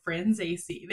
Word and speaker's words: Franzese: And they were like, Franzese.